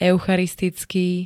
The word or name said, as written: eucharisticky